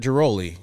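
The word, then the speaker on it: Ghiroli: And I think very interesting, PJ Mooney and Britt Griffel, and I might be saying her name wrong. Griehol. – The Baltimore. – I thought she used to cover the Nationals. – Britt Giroli, you mean? – Oh, yeah.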